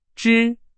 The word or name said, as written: 支